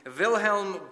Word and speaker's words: Wilhelm: The poet Wilhelm, I got to remember to pronounce my W's with a V, Wilhelm Busch, the poet, he summarizes motherhood well like this.